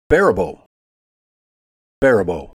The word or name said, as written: Faribault